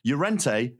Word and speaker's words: Llorente: But Llorente, it took us quite a long time to understand the double, you know, L. – The double L, the soft.